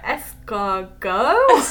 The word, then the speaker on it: escargot: me trying to work at, like, escargot? Snails? – I love that he said escargot as well. – Escargot. Is that how you say it? – That is how you say it.